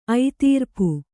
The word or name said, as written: ಐತೀರ್ಪು